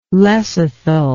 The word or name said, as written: lecithal